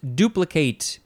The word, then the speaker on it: duplicate: The second form is pronounced duplicate because it is a verb from the 15th century, starting with transitive.